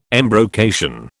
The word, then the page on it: embrocation: British English